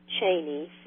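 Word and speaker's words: Cheaney: Chaney, C-H-E-A-N-E-Y. I don't use my first name, Janie Chaney, just because it, you know, sounds a little...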